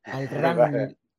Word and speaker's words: Al-Ramli: Al-Ramli. ¿Lo he dicho bien, Muhsin? – Lo has dicho bien. Al-Ramli, con M. – Al-Ramli.